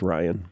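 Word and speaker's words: Rian: whole middle section, I just I felt like, and I could be wrong, but at the time I thought, is it Ryan or Rion? How do we say this name, Johnson? – I think it's Ryan.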